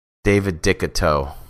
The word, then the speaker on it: Decoteaux: It's French, but it kind of sounds like Dick and Toe. David Decoteaux.